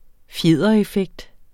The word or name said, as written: fjedereffekt